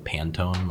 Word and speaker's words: Pantone: But his last name is spelled like Pantone, like the Pantone swatches.